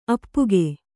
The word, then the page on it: ಅಪ್ಪುಗೈ: kannada